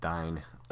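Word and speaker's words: Dáin: Dane Ironfoot. – Yeah. – I think it's technically pronounced Dine.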